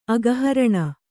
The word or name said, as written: ಅಘಹರಣ